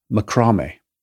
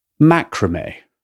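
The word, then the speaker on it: macramé: And as CJ and I ride up in the ski lift together, we make conversation, including how to pronounce the word macrame. He places the emphasis on the first syllable, and I, like a good European, place the emphasis on the second syllable. Macrame.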